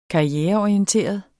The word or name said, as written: karriereorienteret